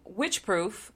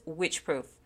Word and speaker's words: Wycheproof: But on a mountain climber scale of lowest to highest Witchproof, this is in Australia, folks, and I promise you, it's spelled Wycheproof, and I heard the pronunciation of it, and it is Witchproof. Of course, it was in Australian accent, but still, it sounds like Witchproof.